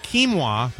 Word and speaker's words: quinoa: Or how about a summer of vegetable and quinoa? That's how you pronounce that, right? – That's right.